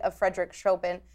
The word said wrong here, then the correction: Chopin: And I feel like I said that wrong. – You did. Chopin.